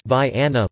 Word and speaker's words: Vienna: Vienna, pronounced Vi-Anna locally, is in Trumbull County, 15 miles north of Youngstown.